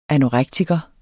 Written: anorektiker

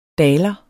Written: daler

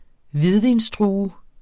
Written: hvidvinsdrue

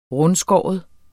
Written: rundskåren

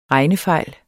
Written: regnefejl

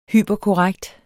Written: hyperkorrekt

